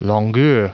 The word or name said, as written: longueur